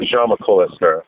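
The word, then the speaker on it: Jaume Collet-Serra: They announced that, I'm going to butcher his name, Wame Koyesera. I'm not sure how you say that. – Yeah, Jaume Koyesera.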